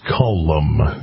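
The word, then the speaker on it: Kulm: And my apologies for saying Culm, because it's actually two syllables in Culm. Is it not? You're the... – been there a number of times at the K-Fire Cafe. It is Culm.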